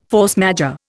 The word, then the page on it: force majeure: Indian